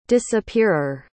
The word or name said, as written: disappearer